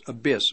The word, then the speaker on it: Abis: It was spelled then A-B-I-S, Abyss, but it is pronounced Abyss, like the abyss of hell.